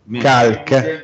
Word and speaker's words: Khalq: E Khalq, lo so, lo pronuncio male. – Khalq. – Ah, sì. Khalq.